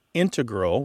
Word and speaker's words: integral: You mainly hear integral with the stress on the first syllable among mathematicians and specialists in scientific fields that use a lot of math. So I don't know if you and your daughter work in those fields, but perhaps that's why you have that pronunciation.